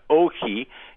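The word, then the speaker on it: ohi (όχι): And the word no, interestingly enough, it's pronounced ohi in Greek, and it's written everywhere.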